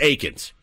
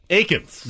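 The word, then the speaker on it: Eakins: By the way, I believe we were saying Dallas Eakin's name wrong for the entirety of our career. I think it's Aikens. – Aikens.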